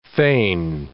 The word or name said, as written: feign